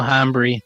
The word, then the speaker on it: hambre: Mucho hambre. – Mucho hambre. – I'm very hungry. That's very hungry. – Thank you. – The H is silent.